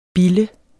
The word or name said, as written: bilde